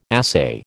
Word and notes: assay: American English

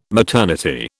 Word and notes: maternity: British English

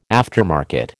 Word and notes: aftermarket: American English

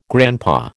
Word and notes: grandpa: American English

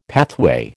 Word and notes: pathway: American English

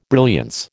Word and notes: brilliance: American English

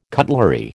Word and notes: cutlery: American English

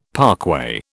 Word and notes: parkway: British English

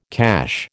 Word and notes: cache: American English

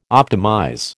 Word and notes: optimize: American English

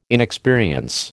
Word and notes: inexperience: American English

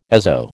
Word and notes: azo: American English

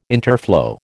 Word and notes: interflow: American English

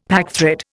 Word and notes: packthread: Indian

